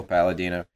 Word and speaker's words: Palladino: It rhymes with my name, Michael Palladino.